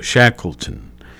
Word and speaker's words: Shackleton: Now, let me make sure I'm saying that right. Yes, I think so. Shackleton. S-H-A-C-K-L-E-T-O-N. He was born on this date in 1874.